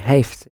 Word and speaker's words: heeft: But he has is an exception. It's got the long A sound. Hij. – Hij heeft.